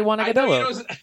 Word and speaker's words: Agudelo: Like Juan Agudelo and all these. – I don't say that. I say Juan Agudelo. I get his name right.